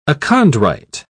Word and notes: achondrite: British English

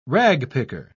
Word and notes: ragpicker: British English

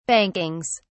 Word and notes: bankings: British English

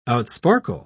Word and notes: outsparkle: British English

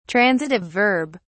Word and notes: transitive verb: British English

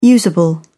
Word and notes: usable: British English

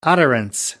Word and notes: utterance: American English

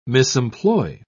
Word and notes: misemploy: British English

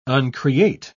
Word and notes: uncreate: British English

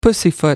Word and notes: pussyfoot: American English

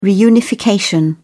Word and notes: reunification: British English

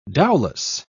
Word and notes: dowlas: British English

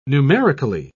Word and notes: numerically: British English

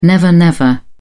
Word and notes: never-never: British English

